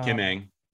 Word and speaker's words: Kim Ng: Kim, um, Kim Ng.